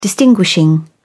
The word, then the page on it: distinguishing: British English